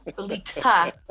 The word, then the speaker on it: Lita: And you that way you can call me Lita, which is two syllables, but you don't have to do the t to spit on people. Lita.